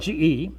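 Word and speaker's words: ge (γῆ): First of all, there's a word ge, it's spelled G-E, pronounced ge,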